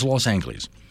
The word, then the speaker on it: Los Angeles: Also in December, a subcommittee in Los Angeles, which is, by the way, how you pronounce that. It is Los Angeles. My childhood hero, Bugs Bunny, called it Los Angeles. Do not tell me that Bugs was wrong.